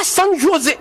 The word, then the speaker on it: San José: on ne dit pas San José, on dit San José. Ce J se prononce comme un H.